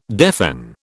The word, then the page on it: deafen: British English